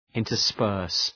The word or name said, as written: intersperse